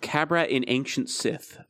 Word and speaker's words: Qabbrat: It is called a Cabrat. It's spelled Q-A-B-B-R-A-T.